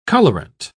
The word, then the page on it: colourant: British English